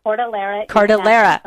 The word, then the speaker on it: Cordillera: because Cordelia, did I say it right? – The Cordillera.